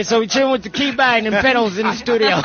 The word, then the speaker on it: Kibini: Did I say it wrong? – Kibine. – Yeah. There you go.